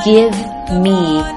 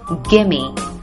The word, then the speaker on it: gimme: 其实这个地方是 give me a hug, honey. 但是呢，在口语当中 give me 可以缩读成 gimme。